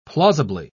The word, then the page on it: plausibly: British English